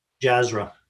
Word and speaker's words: Jafra: So it's Manoj, and how do you pronounce his last name? – Jazra. J-A-F-R-A. – Okay, so Manoj, you've been nominated as a future guest of the Automate and Grow podcast.